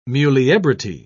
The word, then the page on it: muliebrity: British English